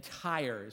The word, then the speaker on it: Tyre: And then they land in Tyre, is how you pronounce that.